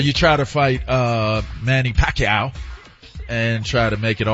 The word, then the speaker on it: Pacquiao: Pacquiao.